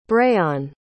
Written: breyon

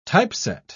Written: typeset

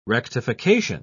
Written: rectification

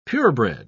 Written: pure-bred

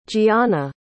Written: jianna

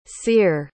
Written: ceire

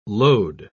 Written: load

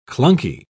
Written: clunky

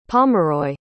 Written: pomeroy

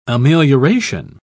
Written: amelioration

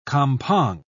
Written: kampong

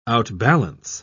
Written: outbalance

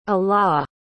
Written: alaw

